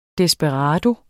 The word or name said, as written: desperado